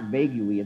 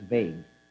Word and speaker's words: vague: Whenever you see an English word that ends with UE, the UE is silent. So it's not vague UE, it's vague. It's not tongue-you-y, it's tongue.